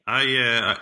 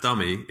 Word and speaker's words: Domi: That's not an email, that's just Domi. – Domi. I think it's pronounced Dummy.